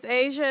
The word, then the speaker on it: Asia: My name is Asia. It is spelled A-S-I-A. – Spell that again? – Like the continent Asia, A-S-I-A.